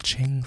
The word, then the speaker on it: Qing: Like this, so we're in the Ching dynasty, which is written Qing with a Q, but I researched and it's pronounced Ching, thank you very much.